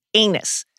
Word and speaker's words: Aenys: Number one, yes, that's how you pronounce it, Aenys. Another thing, he's the second Targaryen king. You get it? Number two. Aenys. – Mm-hmm. – Number two. It's great. George R. R. Martin, folks. What a genius. Aenys took a special interest in the construction of the Red Keep.